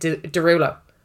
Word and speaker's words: Derulo: His real second name is Desrulo. So it's DESROULEAUX. Desroulox. But it's how you pronounce it, Derulo.